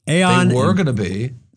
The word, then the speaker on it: Aon: Now, am I saying that right? Aon? – Yeah, sure. – Aon and Willis Towers Watson.